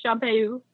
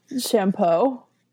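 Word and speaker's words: Champeaux: No, he challenges his former teacher, William of Champeu. – No. Champeau. – Champeu. It's no, more fun. – It's not. Okay. You just really like annoying me with improper French pronunciation, don't you? – I like to poke fun at you.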